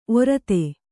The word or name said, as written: ಒರತೆ